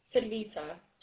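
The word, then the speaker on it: Talitha: Talita, can I spell it for you? – Sure.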